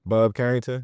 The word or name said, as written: Bub Carrington